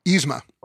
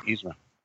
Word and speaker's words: Yzma: Yzma. – Oh, Yzma roller coaster. – Yzma.